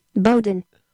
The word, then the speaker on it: Bowdoin: Bowdoin.